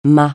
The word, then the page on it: mas: francais